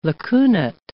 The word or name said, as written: lacunate